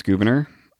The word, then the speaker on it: Gouverneur: It really is pronounced Gouverneur.